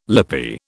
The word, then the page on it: lippy: British English